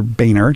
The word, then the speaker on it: Boehner: The problem with historically is that Speaker Ryan and before him Speaker Boehner. Look, I even pronounced it correctly, not like I did during the break.